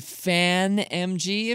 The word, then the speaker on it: FAANMG: It's F-A-A-N-M-G. Now, for our purposes this week, I'll be pronouncing that as FANG stocks, but F-A-A-N-M-G could be FAN-M-G, if you like.